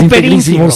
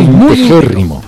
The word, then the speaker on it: integérrimo: No se dice integrísimo, se dice integérrimo.